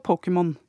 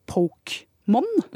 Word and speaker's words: Pokémon: Eh, fordi engelskuttalen er jo også Pokémon. Og hvis ikke den sto der, så ville vi kanskje funnet det naturlig å si Pokemon.